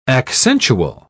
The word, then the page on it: accentual: British English